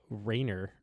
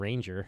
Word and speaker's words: Ranger: who we found out apparently we were pronouncing his name wrong. It's Rainer or something like that instead of Ranger.